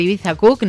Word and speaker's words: Ibiza Cook: organizado por Ibiza Cook, no sé si lo digo bien. – Sí, muy bien.